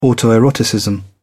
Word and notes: autoeroticism: British English

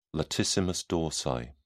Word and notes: latissimus dorsi: British English

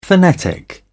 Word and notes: phonetic: British English

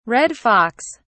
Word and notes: red fox: British English

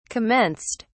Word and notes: commenced: British English

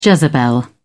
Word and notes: jezebel: British English

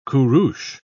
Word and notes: kurus: British English

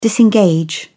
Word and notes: disengage: British English

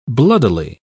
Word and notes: bloodily: British English

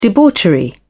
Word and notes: debauchery: British English

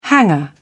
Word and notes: hanger: British English

